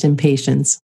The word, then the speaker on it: Impatiens: And here in the States, it is pronounced impatience.